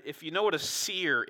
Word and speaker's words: Seir: If you read seer in the Bible, S-E-I-R, like it's spelled here, it's talking about Edom, but it's also talking about their practice of divination and different things.